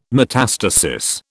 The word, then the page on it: metastasis: British English